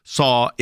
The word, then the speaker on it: Cao: So it's Pastor Saw. If you see the email, like if you get our emails, it's in there today, and it says we're working to free Pastor John Saw, but it's spelled C-A-O.